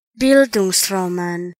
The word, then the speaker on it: Bildungsroman: No one told us how to pronounce Bildungsroman. So I'm just going to insert audio right here from a YouTube site about how to pronounce it. – Bildungsroman. – All right. That's how you pronounce it.